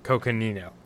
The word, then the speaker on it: Coconino: Coconino.